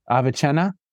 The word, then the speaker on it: Avicenna: Rick, did I say that correctly? Avicenna? – Oh, yeah, definitely.